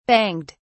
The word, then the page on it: banged: British English